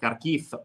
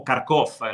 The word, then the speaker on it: Kharkiv: E volevo anche dirvi che Kharkiv, o Kharkov, la pronuncia in russo, è una città importante, la seconda città più grande dell'Ucraina dopo Kiev, 1,5 milioni di abitanti.